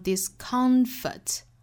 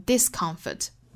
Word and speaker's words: discomfort: Sometimes a little discomfort 这个单词一般来说重读是在中间这个音节的，所以读成 discomfort 会有特别特殊的情况发生重音转移，比如说尤其强调这个词里面的不舒服的这个不的概念。就有可能会把这个词读成 discomfort